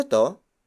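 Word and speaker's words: 'to: And then it is followed by the word to. To. To. To is spelled as apostrophe T O it means this.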